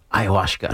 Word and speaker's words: ayahuasca: It has nothing to do with Alawaska. How do you say it again? – Ayahuasca.